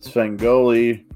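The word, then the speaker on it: Svengoolie: Svengoli, pronounced Ghouli.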